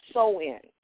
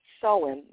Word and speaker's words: Samhain: Now, the celebration of Hallow's Eve honored the pagan of the Celtic god pronounced Samhain. It's spelled S-A-M as in Mary, H as in Harry, A-I-N. Samhain, the Lord of the Dead.